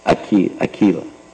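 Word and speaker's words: Akhila: Ekala Rasmaritu Murti. How do you say that first word again? What's the first word? Akila. Akila Rasmarita Murti.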